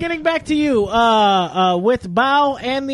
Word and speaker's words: Bao: Bao.